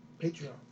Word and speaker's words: Patreon: Patreon. Did I say it right? – Yeah, you did.